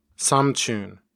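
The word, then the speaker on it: samchun (삼촌): Specifically, the word for uncle, samchon, is usually pronounced as samchun.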